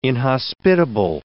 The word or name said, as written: inhospitable